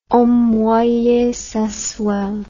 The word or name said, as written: homme moyen sensuel